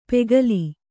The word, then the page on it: ಪೆಗಲೀ: pegalī